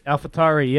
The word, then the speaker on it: AlphaTauri: Is it AlphaTauri or whatever how you pronounce it? – AlphaTauri, yep.